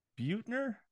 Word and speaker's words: Buettner: By Dan Buettner. I think that's how you say it. – Yeah, you did good.